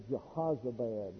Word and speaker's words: Jehozabad: That's actually Jehozabad. It's a short O.